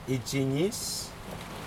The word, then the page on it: ijiNis: Aneityum